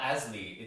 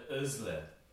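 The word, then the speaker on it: Aslı: It's not Asli, it's like Özle. Özle.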